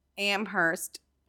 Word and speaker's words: Amherst: It said it said Amherst, Massachusetts, where the only thing silent is the H. – Amherst. Amherst. – Amherst because the idea is you don't pronounce the H.